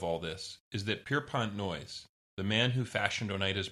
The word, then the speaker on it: Noyes: And their charismatic leader was a man named John Humphrey Noyes. So, yeah, his last name is spelled N-O-Y-E-S, which can be a little confusing in an audio podcast.